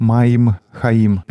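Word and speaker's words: Маим Хаим (מים חיים): «Живая вода» произносится как «Маим Хаим».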